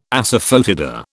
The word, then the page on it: asafoetida: British English